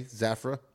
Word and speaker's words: Zafra: Zafra, Z-A-Z-A-Z-A-F-R-A, Zafra.